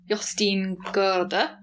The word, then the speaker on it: Jostein Gaarder: Yeah, the two kind of chapter books that we've enjoyed most were The Christmas Mystery by, I've been learning how to say his name, something like Jostein Gaarder. – Very good. – I used to call him Jostein Gaarder, but yes.